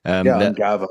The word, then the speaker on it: Ungava: Ungava.